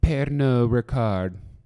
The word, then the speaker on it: Pernod Ricard: and I'm pretty sure that you don't pronounce the D, which is right. It's actually Pernod Ricard.